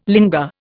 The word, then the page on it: linga: Indian